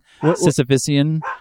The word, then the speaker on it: Sisyphean: You're mispronouncing Sisyphean. – No, wait, I'm sorry. – Sisyphean. – Because it's about Sisyphus. – So Sisyphean.